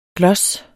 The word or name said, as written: gloss